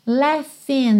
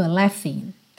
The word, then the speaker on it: laughing: Leffen, não se assuste com a escrita dessa palavra, porque a escrita é L-A-U-G-H-I-N-G, mas a pronúncia é Leffen. Esse GH do meio vai soar como um F. F, Leffen, tá?